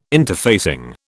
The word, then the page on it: interfacing: British English